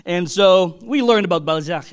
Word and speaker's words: Balzac: I said, "Is that how you say it?" "It's French, Balzac."